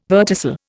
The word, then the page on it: verticil: Indian